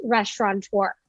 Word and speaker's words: restaurateur: Restaurateur, like the Mouseketeers.